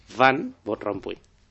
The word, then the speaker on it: Van Rompuy: Van Rompuy o Rompuy. ¿Cómo se dice? – Van Rompuy. – Van Rompuy. – Pues más o menos. – Hay que decirlo con violencia.